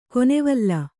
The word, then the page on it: ಕೊನೆವಲ್ಲ: konevalla